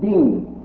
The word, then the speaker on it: deen: Deen. It's the letter D, dash, and the letter E, ye, called ye, pronounced E, when it's silent, and the letter N, noon, deen. D-E-E-N is spelled, I spell my name, but it can be spelled D-I-N. Deen, pronounced deen.